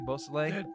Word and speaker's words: Beausoleil: I said that correctly, Beausoleil? – Yes, you did.